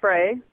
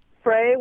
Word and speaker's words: Fray: My name is Frey. – Frey? – Frey with an F. – How do you spell that? – F-R-A-Y. – All right, Frey. – Frey.